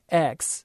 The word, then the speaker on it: LaCroix: It's spelled LaCroix. And so the inclination is to pronounce it how I was before in the French way.